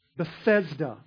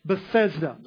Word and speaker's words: Bethesda: So it says in Aramaic, this gate or this pool is called Bethesda. I did say that wrong. Bethesda.